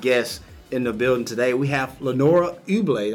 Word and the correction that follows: Ebule: Am I saying that right? – Ebule. – Ebule.